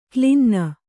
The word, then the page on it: ಕ್ಲಿನ್ನ: klinna